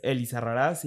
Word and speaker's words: Elizarrarás: Y de hecho aquí, por ejemplo, mi apellido se escribe con acento. Es Elizarrarás y lleva acento en la última.